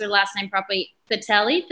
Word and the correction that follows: Thattil: Fatelli, Thattil.